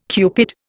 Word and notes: Cupid: Indian

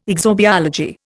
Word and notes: exobiology: Indian

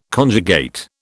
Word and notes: conjugate: British English

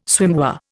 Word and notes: swimwear: Indian

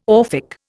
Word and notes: Orphic: Indian

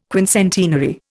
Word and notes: quincentenary: Indian

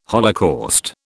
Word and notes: holocaust: British English

